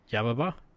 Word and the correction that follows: Yubaba: Yep. Yubaba. So Yubaba. – Oh, Yubaba. – Yubaba. There we go.